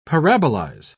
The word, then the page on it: parabolize: British English